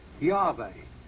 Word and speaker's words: Yahweh: I want you to note here that this is pronounced Yahweh.